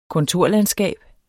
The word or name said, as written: kontorlandskab